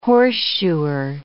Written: horseshoer

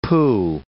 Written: pooh

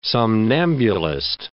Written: somnambulist

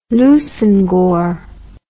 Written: Leveson-Gower

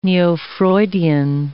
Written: neo-Freudian

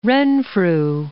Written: Renfrew

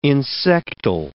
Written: insectile

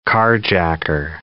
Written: carjacker

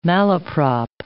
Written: malaprop

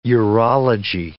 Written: urology

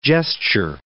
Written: gesture

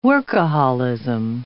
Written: workaholism

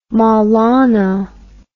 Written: maulana

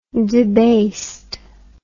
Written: debasedness

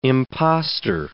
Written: imposter

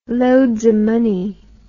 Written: loadsamoney

